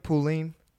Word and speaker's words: Pauline: But before we, am I saying your name right, Pauline? – Yeah, or Pauline. – Okay. – You know, just the American way, like with an A.